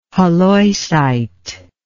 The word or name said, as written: halloysite